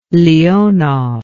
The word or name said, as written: leonov